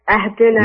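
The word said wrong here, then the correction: اهدنا: لا مش أهدنا. لا إهدنا.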